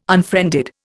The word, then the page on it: unfriended: Indian